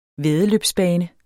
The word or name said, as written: væddeløbsbane